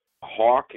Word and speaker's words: Hack: Hawk is definitely, and I know it's spelled Hack, but it's definitely pronounced Hawk.